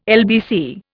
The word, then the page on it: LBC: Indian